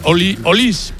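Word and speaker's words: Olise: Olis, porque en francés todas las finales que no van acentuadas.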